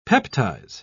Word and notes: peptize: British English

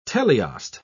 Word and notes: teleost: British English

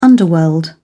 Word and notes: underworld: British English

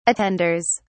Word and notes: attenders: British English

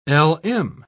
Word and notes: lm: British English

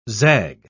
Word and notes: zag: British English

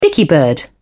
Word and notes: dicky-bird: British English

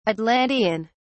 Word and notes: atlantean: British English

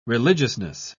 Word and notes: religiousness: British English

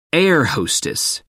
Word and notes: air-hostess: American English

